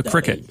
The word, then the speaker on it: Cricut: Meanwhile, in between that, Jerry from Rochester asked, since you are talking about 3D printers today, my wife has a Surcut automated... – Uh-oh, Cricut. A Cricut. Yep. – How's it pronounced? How's it spelled? – C-I-R-C-U-T, automated paper cutter to make scrapbook stuff.